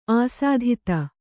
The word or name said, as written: ಅಸಾಧುತೆ